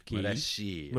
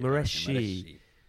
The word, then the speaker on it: Moreschi: I think it might be Alessandro, either Moreschi. – Moreschi. – Moreschi. M-O-R-E-S-C-H-I. So it, Moreschi probably sounds better.